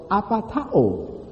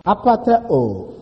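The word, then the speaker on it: Apatao (ἀπατάω): The first use, deceived, comes from the Greek word apatao. Apatao, A-P-A-T-A-O-O, or just A-O. Apatao, A-P-A-T-A-O. The long O in the Greek, sometimes people put O-O to show that it's a long O.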